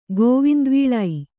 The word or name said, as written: ಗೋವಿಂದವೀಳೈ